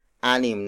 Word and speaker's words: anim: Up next is anim.